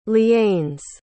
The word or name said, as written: lianes